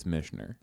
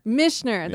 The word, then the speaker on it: Michener: And I think Michener is how you pronounce it. – I believe it's Michener. – Michener. That's what it is.